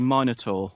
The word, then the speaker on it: Minotaur: I have a Minotaur a Minotaur is how the English say it, is that right? – Yeah, I would say Minotaur.